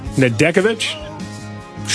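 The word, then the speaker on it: Nedeljković: How do you pronounce the backup guy? Nedeljkovic? – Sure. Sure.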